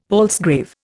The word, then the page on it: palsgrave: Indian